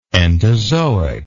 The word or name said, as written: entozoic